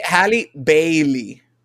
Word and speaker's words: Halle Bailey: Eh, Halle, Bailey.